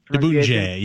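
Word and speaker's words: Diboundje: I mean, I think on the wing, Quentin Dabunje, I believe is the pronunciation. – Dabunje, yeah, in French. Dabunje.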